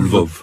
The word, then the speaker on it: Lvov: Lvov.